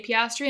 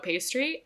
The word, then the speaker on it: Piastri: It's also, there's a literal clip on our TikTok of Emma's brain breaking when she's trying to say Piastri and it just comes out pastry.